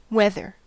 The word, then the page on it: weather: American English